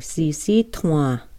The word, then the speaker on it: Sisseton: Those are our Sisseton relatives.